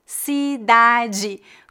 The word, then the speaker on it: cidade: Por exemplo, na palavra cidade, a letra DE em final de sílabas é pronunciada como G. Cidade. Como você pronuncia essa palavra em Portugal? – De ou di, depende. Qual foi a palavra que tu usaste? – Cidade. – É, nós dizemos cidade. Cidade. Portanto, de é de.